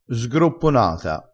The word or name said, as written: sgropponata